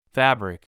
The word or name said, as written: fabric